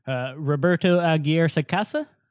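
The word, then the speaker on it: Roberto Aguirre-Sacasa: And it's also even more clear because the creator, who's very, his name is, do you know how to say his name properly? – Roberto Aguirre Sacasa? – There you go.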